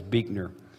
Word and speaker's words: Buechner: It's spelled Buechner, but it's proper pronunciation, so I'm told. I've never met him. It's Buechner.